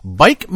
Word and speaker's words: Buyck: If you're interested, 7236 Far North Road, Bike, Minnesota. Spelt B-U-Y-C-K, pronounced bike, apparently.